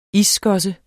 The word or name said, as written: isskosse